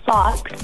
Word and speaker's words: Fawkes: And then I have one more puppy that I adopted last month, and her name is Fox, and it's spelled F-A-W-K-E-S after Dumbledore's phoenix, because yes.